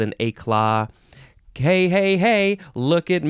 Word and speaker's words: éclat: This word is éclat. Yeah, you can emphasize either syllable, éclat or éclat.